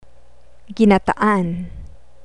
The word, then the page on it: ginataan: Tagalog